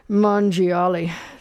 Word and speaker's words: Mangiali: But as Don from Beloit was mentioning, he is his given name, which I think Don mangled, but John Mangiali. The second G is silent, Don.